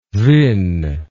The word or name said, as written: vin